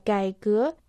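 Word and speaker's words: 改革: «Кай» произносится в третьем доне, «гэ» — во втором доне. Слово «гайгэ» переводится как «реформа».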